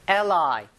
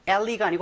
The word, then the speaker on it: ally: ally, ally가 아니고 ally라고 읽는데요.